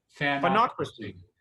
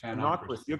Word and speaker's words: Fanocracy: How do you say that? – Fanocracy. Yeah, it's like democracy, but with fans. – Fanocracy.